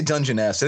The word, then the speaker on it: Dungeness: Okay, so I've been pronouncing this wrong the whole time. You said Dungeness. How do you say it? – Dungeness. – I say Dungeness. I think both are acceptable.